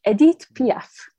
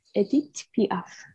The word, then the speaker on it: Edith Piaf: And I want to pronounce Edith properly. Edith Piaf. Edith Piaf.